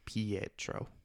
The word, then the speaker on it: Pietro: Pedro. Did I say it wrong? – There's just like, you almost say Pedro. There's a like Pietro.